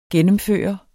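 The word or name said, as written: gennemføre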